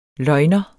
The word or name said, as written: løgner